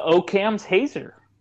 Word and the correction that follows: Occam's Hazer: It's Occam's Hazer. – Occam's Hazer. – As in Occam's Razor? – Whoop-dee-doo, Basil.